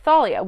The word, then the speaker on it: Thalia: My entire life, everybody has been pronouncing it Thalia. – Yeah. – But then in the show, they pronounce it Thalia, which is technically the correct way to say it.